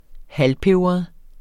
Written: halvpebret

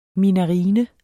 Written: minarine

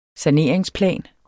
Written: saneringsplan

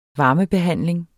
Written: varmebehandling

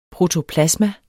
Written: protoplasma

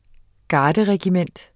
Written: garderregiment

